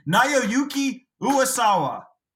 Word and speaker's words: Naoyuki Uwasawa: Naoyuki Uwasawa.